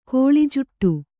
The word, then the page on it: ಕಳೆಜೂಟ: kaḷejūṭa